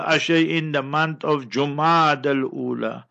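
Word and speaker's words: Jumada al-Ula: It is Jumada with the Dhamma Pesh on the Jim. Jumadal Ula. So if the crescent is sighted, that will usher in the month of Jumadal Ula.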